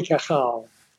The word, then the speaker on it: Cajal: What you're talking about is Ramon y Cajal. That's pronounced Cajal. – Cajal. I said it a little bit wrong. I said Kuhal. Cajal. Thank you.